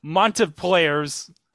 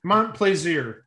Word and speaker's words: Montplaisir: Three separate tiebreaker clues had to be played before Chris Montplaisir's. Oh, that's a difficult name to pronounce. – Montplaisir. – Okay. What kind of name is that? – French.